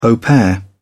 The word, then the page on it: au pair: British English